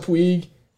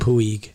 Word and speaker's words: Puig: They have Yasiel Puig. – Yeah. – Did I say that correctly that time? – Yeah, you're straight away from Puig.